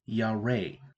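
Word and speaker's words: yare (ירא): The Hebrew word for fear is yare. Yare sounds close to Yahweh, but that's not at all the way it's spelled.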